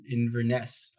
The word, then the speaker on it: Inverness: And then September, uh, I'm going to Scotland, flying into in Inverness, I believe is the name of the city.